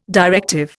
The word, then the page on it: directive: Indian